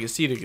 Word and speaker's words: acetic: Maybe I'm saying this wrong. – It's like acetic, yeah. – A-C-E-T-I-C.